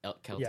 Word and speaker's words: Celtic: Celtic. Yeah, phonetically it is K-E-L-L-tick. So Celtic.